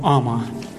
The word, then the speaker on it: ама: Значит, на санскрите фактор, который называется токсина, называется словом «ама». На первый слог ударения.